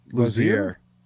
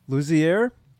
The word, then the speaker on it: Luzier: Luzier. – Luzier.